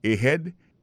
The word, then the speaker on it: ahead: Ahead. Ahead, là, c'est A-H-E-A-D. Ahead. Ahead. Go ahead. Là encore, on a vu que l'accent tonique là tombé sous deuxième syllabe.